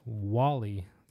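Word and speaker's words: Guale: The Florida Spaniards pronounced Gwale Indians Wally. So they called them Wally Indians.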